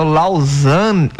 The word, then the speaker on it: Lausanne: É o Lausanne.